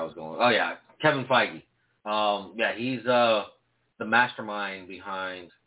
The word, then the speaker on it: Feige: And it's pronounced Feige. – Whatever. That's not how I say it.